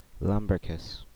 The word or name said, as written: Lumbricus